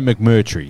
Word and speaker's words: McMurtry: The McWorty? McMurtry. Sorry, McMurtry.